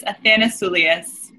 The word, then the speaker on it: Athanasoulias: My full last name is Athanasoulias.